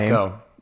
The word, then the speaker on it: Pacheco: But they have somebody, Pacheco. I forgot how you pronounce his last name. – Pacheco. – Yeah. – Isaiah Pacheco.